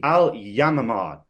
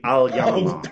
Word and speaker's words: Al Yamamah: The Amamaha deal. Am I pronouncing that correctly? It's called It's called the Al Yamamah deal. It's, I believe, Arabic for dove. The Al Yamamah.